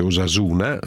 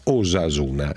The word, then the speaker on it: Osasuna: Devo ringraziare Marino, che scrive da Nole, provincia di Torino, per correggermi la pronuncia Osasugna. Ha perfettamente ragione, si legge invece Osasuna senza il GN, e mi spiega che la ragione è semplice, perché questa è una parola basca che significa salute e va pronunciata in quella maniera, Osasuna.